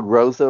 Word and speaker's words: Groza: He won the Lou Groza, am I saying that right? – Yeah. – Lou Groza Award for the nation's top kicker in 2019.